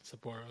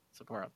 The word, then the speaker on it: Sapporo: I said it right that time. – Sapporo, yeah. – Sapporo, okay.